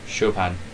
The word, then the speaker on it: Chopin: Chopin.